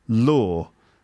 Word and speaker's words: lure: Lure. Can you pronounce that? Lure. It's, it's, it's spelled L U R E.